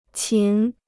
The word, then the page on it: 琴: qín